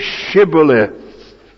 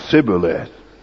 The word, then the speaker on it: Shibboleth: "Well then, say Shibboleth." And if he said Sibboleth, because he couldn't pronounce S-H.